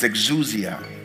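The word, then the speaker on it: exousia (ἐξουσία): The second one is exousia. E-X-O-U-S-I-A. E-X-O-U-S-I-A. So the first one is dunamis. D-U-N-U-N-A-N-I-S. Okay, it's D-U-N-A-N-I-S.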